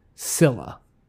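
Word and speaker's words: Csilla: Oh, so I can either go with a silent C or I can pronounce the C real quickly. I'm going to go with silent C. Is the capital called Scylla? – Yeah, it's silent.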